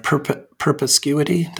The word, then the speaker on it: perspicuity: I mean, it sort of rubs up against the doctrine of perspicuity.